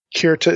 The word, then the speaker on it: Kirta: You'll see it referred to both ways. Karaite is spelled K-E-R-E-T. Kirta is K-I-R-T-A.